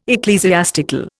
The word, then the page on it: ecclesiastical: Indian